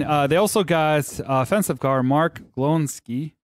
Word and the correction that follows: Glowinski: Did I say it right or not? – Glowinski. – That's what I said.